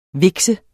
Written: vikse

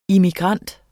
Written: immigrant

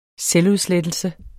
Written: selvudslettelse

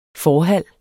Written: FORHALLER

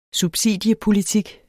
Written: subsidiepolitik

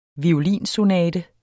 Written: violinsonate

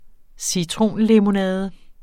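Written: citronlemonade

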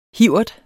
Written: hivert

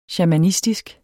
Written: shamanistisk

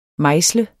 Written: mejsle

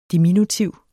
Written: diminutiv

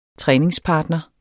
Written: træningspartner

